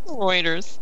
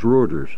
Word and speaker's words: Reuters: What is the right way to say that? – Reuters. – It's spelled R-E-U, so it's Reuters.